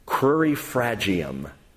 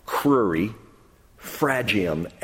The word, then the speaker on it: crurifragium: Crurifragium. I'll spell it for you. C-R-U-R-I, crurifragium, F-R-A-G-I-U-M. It's a Latin word, crurifragium.